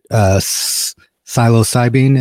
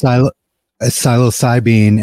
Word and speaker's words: psilocybin: I believe it's, was it psilocybin? Is that how you pronounce it? – Correct. – Psilocybin and its effects on treating depression.